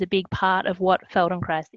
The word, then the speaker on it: Feldenkrais: And I guess, and I hope I'm saying this correctly, Feldenkrais? Feldenkrais? Yeah. Feldenkrais, Feldenkrais, Feldenkrais.